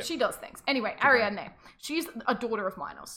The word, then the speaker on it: Ariadne: Her name's Ariadne. – Oh, Ariadne. – Yeah, Ariadne.